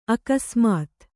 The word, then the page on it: ಅಕಸ್ಮಾತ್ತು: akasmāttu